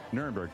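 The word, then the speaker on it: Nirenberg: Mayor Ron Nuremberg, am I saying it right? – Nuremberg, that's correct.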